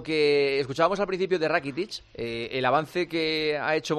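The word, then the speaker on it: Rakitić: Bueno, total, esto ha dicho Rakitic, que se pronuncia así, Iván Rakitic, sobre cómo está ahora en el Barça.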